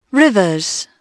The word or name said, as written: rivers